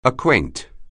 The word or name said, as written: acquaint